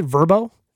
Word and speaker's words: VRBO: Or do I have to pronounce it? Verbo? Is that what it is? – It is.